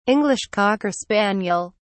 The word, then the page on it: english cocker spaniel: British English